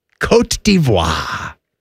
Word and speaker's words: Côte d'Ivoire: Côte d'Ivoire.